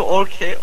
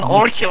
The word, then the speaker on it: Orca: Evet, ekran okuyucumuzun adı Orce. Orce. Yani okunuşu Orke. Orce diyebilirim ben. – Orca diye yazılıyor ama okunuşun için değil. – Ben Orki olarak biliyorum ama Orki, tamam.